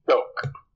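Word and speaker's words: Xoc: Shok. – What was that? – The Shok. – Shok. Okay. Shok. – X H. It's basically the S H. Okay. Right? Shok. The X is like an S H.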